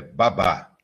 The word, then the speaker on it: Babá: É Baba ou Babá? Tem um jeito mais certo de falar? – A pronúncia é Babá.